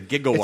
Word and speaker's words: gigawatt: So when it was actually a lot of people are surprised to find out that it's not JIGG, it's GI, it's a gigawatt.